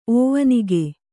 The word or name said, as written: ಓವನಿಗೆ